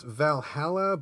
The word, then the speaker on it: VA-11 Hall-A: I guess it's pronounced Valhalla, but it's written out as VA-11. Hall-A.